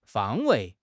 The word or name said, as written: 方位